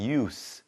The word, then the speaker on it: use: 그리고 한 가지 더 알려드리면 동사일 때는 발음이 use 이렇게 z 발음이 납니다. 하지만 명사일 때는 use s 발음이 난다는 거 참고하시기 바랍니다.